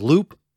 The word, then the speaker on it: Luup: I looked it up, and actually, the one I wanted to mention is Loop. It's spelled L-U-U-P,